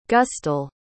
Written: gustel

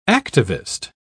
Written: activist

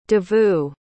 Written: davu

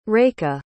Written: reika

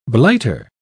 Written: blighter

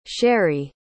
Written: sharee

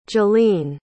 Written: jillene

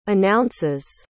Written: announces

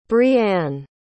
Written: brie-ann

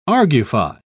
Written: argufy

